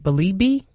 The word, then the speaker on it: Belibi: The dunking sensation, Fran, and I have to, she said I said her name right, Belibi.